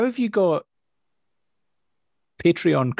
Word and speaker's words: Patreon: the, the amount of times that in my head that I go Patron. No, it's not.